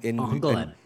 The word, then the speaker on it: Angoulême: In Angoulême. – Angoulême? Okay, that's how you pronounce it.